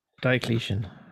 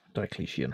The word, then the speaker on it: Diocletian: Diocletian. And I had to I had to write down how to pronounce Yes. – Correct. That's correct. Diocletian.